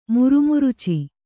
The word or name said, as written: ಮುರುಮುರುಚಿ